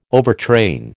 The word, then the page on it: overtrain: American English